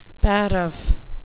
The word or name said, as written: paraph